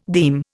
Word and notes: deem: Indian

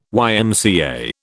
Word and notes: YMCA: British English

